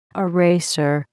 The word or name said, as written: eraser